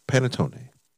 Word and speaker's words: panettone: And then you also have a brand new item, the Panotte, right? Did I say that right? – Panettone. – Traditional Italian panettone.